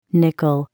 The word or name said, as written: nickel